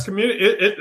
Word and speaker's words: CXMMUNITY: Yes, Community.